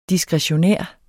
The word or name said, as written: diskretionær